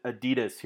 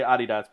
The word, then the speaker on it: Adidas: We pronounce it Adidas here in the U.S. – Yeah. so it's really weird for me to say Adidas, but you know, yeah. international or audience. As, or so as on. we say, you pronounce it wrongly. – Which I get because it's Adi Dassler, so I, I, get the pronunciation of Adidas.